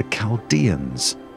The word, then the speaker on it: Chaldeans: It started in a place called Ur, which rhymes with fur, ruled by a people called the Chaldeans, which rhymes with Schmaldeans.